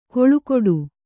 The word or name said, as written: ಕೊಳುಕೊಡು